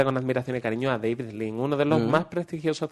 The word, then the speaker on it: David Lean: David Lean.